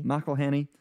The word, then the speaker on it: McElhenney: McElhenney.